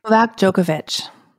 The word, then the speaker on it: Djokovic: So I don't know how to say this tennis player's name. – Novak Djokovic.